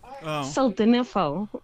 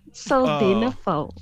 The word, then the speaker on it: Sildenafil: Sildenafil. Sildenafil.